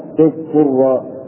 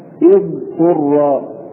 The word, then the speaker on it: اضطر: وأيضا أظهر الضاد في كلمة انقر، بعض الناس يقرؤونها تضطر، يضغمون الضاد بالطاء، والصواب اضطر، اضطر.